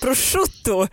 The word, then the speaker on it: prosciutto: Prosciutto. Men man säger väl ändå bruschetta? – Det är mjuk vokal och då blir chi. Prosciutto.